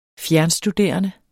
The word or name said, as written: fjernstuderende